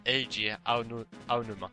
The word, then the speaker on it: Eiji Aonuma: Och i hans ställe så har, har då en herre vid namn Iji Aonuma fått mer input. Och ni som kan japanska får gärna tala om för mig hur mycket jag slaktar det där uttalet. – Det uttalas Eiji Aonuma.